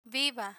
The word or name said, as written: viva